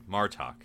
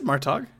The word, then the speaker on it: Martok: Anyways, Gowron it is Martok. Yes, okay. You had some interesting pronunciations there. I just wanted to. – I said Martog.